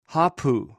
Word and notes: hopou: greek

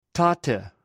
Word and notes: tote: greek

